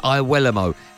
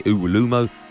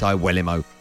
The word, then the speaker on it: Iwuelamo: Alongside Hugh was Darren Lewis from The Mirror and former footballer Chris Iwuelamo. And yes, I am pronouncing his name correctly. Lots of you say Uwulumo. You are Uwulu wrong. It's Iwuelamo, he told me.